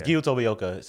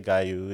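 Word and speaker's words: Giyu Tomioka: So it's Gyu Tomioka. Some people might say Gyu. Some people might say Gaiu. It's Gyu.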